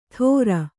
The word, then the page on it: ಥೋರ: Kannada